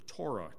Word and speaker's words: Torah: In verse 92, he uses the word law, which means the Torah, or the Torah, depending on how you pronounce it, which means law or direction or instruction.